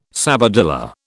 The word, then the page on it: sabadilla: British English